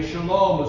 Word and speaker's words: Shalom: Shalom is the way they pronounce it today.